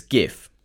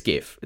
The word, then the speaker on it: GIF: I'm going to discuss the correct pronunciation of GIF. It's GIF. That's it. That's all there is to it. It's GIF. It's not GIF. If you say GIF, you're wrong.